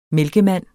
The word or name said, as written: mælkemand